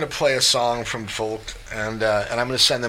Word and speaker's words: Volk: Well, you know, if you speak German, you pronounce the V with an F, so you pronounce Folk. That's like Volkswagen is Volkswagen.